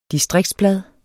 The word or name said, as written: distriktsblad